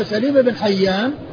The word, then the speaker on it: سليم بن حيان: سليم وسليم المكبر مفتوح السين مكسور اللام، والمصغر مضموم السين مفتوح اللام. فشخص واحد هو سليم بن حيان مكبر، ومن عدا هذا المكبر فهو مصغر سليم، سليم وسليم.